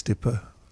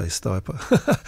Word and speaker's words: Stipa: I think if you pronounce it, it's Latin. I think it's Stipa. If you speak to a lot of botanists, they say Stipa.